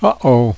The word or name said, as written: uh oh